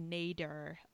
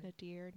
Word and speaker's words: nadir: But I think that was really maybe the nadir of, is that how you pronounce that word? That word? – It sounds good. – Nadir, nadir, of Oz adaptations.